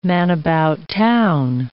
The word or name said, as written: man-about-town